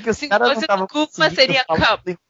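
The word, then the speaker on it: Qapla': Sua pronúncia tá errada, tá? É Kapla!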